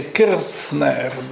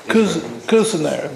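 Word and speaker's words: Kirzner: I guess in Germany they would say Kirchner, but he lives in America. No, yeah, that's right, sorry. Kuzner, they pronounce it in America.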